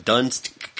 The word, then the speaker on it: Donetsk: Donetsk. It's done. The word done. – Yeah. – T-S-K.